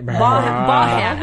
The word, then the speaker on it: Baughan: So, Dr. Emily Brahan, lecturer... – And you have to say it like that, right? – Bahan. I'm going to say Bahan. B A U G H A N.